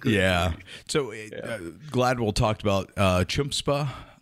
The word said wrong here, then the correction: chutzpah: Am I saying that right? – Chutzpah.